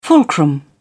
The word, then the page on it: fulcrum: British English